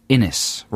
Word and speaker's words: Innes: All right, this is from Scott Innes, rhymes with Guinness.